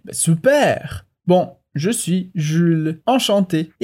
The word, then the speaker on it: enchanté: E aqui é importante que enchanté termina em E accent aigu, o E com acento agudo. Portanto, não tem diferença na pronúncia entre masculino e feminino.